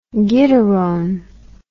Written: gittarone

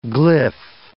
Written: glyph

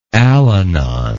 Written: Al-Anon

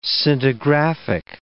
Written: scintigraphic

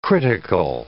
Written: critical

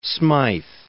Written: Smyth

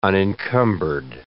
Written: unencumbered

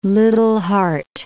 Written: Liddell Hart

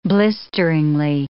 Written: blisteringly

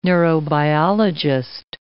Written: neurobiologist

